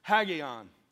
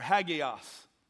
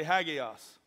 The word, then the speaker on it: hagios: Peter's using a Greek word right here that is said like this. Hagion or hagios. Say hagios.